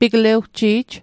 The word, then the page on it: piglewji'j: Mi'gmaq